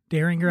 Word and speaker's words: Derringer: Am I saying that right, Derringer? – Derringer. – I have a hard time. – Derringer. – Sure. The soft G. There we go.